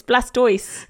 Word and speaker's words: Blastoise: Mega Blastoid. Actually, I said it wrong. It's Blastoise, is it? – Yeah, I don't Pokemon.